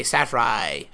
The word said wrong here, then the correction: Seyfried: Amanda Safray. I don't know if that's saying her name right. – Seyfried.